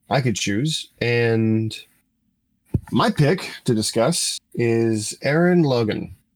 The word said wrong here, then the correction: Gurren Lagann: I said that wrong. I said that very wrong. I said that so wrong. Give me a moment, sir. – Oh, God. – You're a dick. Crap. How do you say it? – Do you mean Gurren Lagann? – Yes, that's...